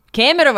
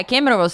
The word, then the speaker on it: Kemerovo: Kemerovo, right. Yeah, Kemerovo.